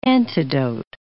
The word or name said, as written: antidote